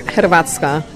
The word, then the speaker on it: Hrvatska: HRV stands for Hrvatska. That's how we pronounce it.